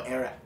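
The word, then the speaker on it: airag: How do I pronounce this? Irag? – Oh, Arag.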